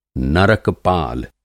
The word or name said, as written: नरक-पाल